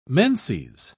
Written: menses

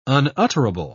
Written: unutterable

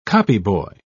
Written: copyboy

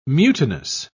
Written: mutinous

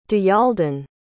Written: diyaaldin

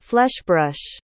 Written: fleshbrush

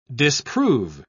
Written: disprove

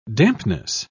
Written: dampness

